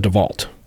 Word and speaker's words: DeVault: Yeah, and it's spelled DeVault. When we were working this case, mm-hmm. it was Marissa DeVault.